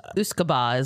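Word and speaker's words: uisge beatha: Go ahead and pronounce that. – Uscaba is what I was going to say. Mm-hmm. – Yeah, that looks good.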